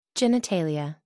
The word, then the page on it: genitalia: American English